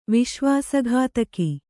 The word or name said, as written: ವಿಶ್ವಾಸಘಾತಕಿ